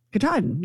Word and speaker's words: Katahdin: Katahdin, can you do it? – Yeah, Katahdin. – Katahdin, yeah.